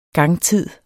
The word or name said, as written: gangtid